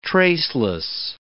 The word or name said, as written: traceless